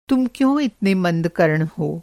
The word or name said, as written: मंद-कर्ण